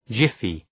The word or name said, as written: jiffy